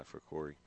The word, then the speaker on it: Ficuri: I asked Coach how to say his name. He said, just like Missouri, but with an F. Missouri Ficuri, he said.